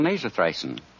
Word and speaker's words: Nasothricin: So if a cold in the nose threatens to hinder your holiday enjoyment, I suggest you get a bottle of Rexall Nasothricin. That's spelled N-A-S-O-T-H-R-I-C-I-N.